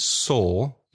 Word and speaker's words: sore: You could say that something is sore, S-O-R-E, exactly the same pronunciation as the past of to see, so sore and sore.